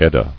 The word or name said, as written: Edda